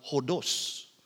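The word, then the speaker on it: hodos (ὁδός): The basis of that is the noun odos. In Greek, we pronounce it hodos.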